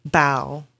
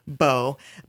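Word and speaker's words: bao: And I see a note here for homemade boa. – Bow. – Oh, okay. – Yeah, so that's actually one of our board members. Wesley makes amazing bow. Sorry, yeah, I said it wrong, too.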